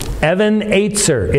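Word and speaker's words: Even Ezer: Can someone say it right? Evan Aitzer. It's a Hebrew phrase. Evan is stone. Aitzer, help. Stone of help.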